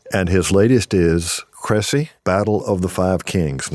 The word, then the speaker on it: Crécy: Now, I use the English pronunciation, but Michael, you would say something else. – I would say Cressy, yeah. It's a word that there's sort of the technical French way of saying it.